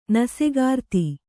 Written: ನಸೆಗಾರ್ತಿ

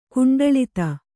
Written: ಕುಂಡಳಿತ

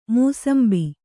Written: ಮುಸುಂಬಿ

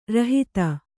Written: ರೋಹಿತ

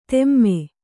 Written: ಧೂಮ

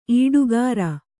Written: ಈಡುಕಾರ

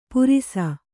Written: ಪುರಿಸ

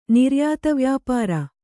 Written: ನಿರ್ಯಾತವ್ಯಾಪಾರ

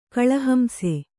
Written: ಕಳಹಂಸ

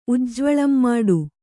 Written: ಉಜ್ಜ್ವಳಂಮಾಡು

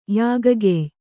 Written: ಯಾಗಗೆಯ್

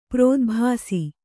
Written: ಪ್ರೋದ್ಭಾಸಿ

